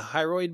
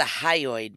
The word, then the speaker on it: hyoid: The hyoid bone? – It's the hyoid bone.